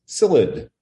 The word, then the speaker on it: psyllid: And the quarantine is for the Asian citrus psyllid, P-S-Y-L-L-I-D. The P is silent.